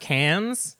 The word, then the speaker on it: Cannes: Cannes? – I don't know. – It's pronounced Cannes. – Okay. How do you know? – Because I've heard of the Cannes Film Festival before.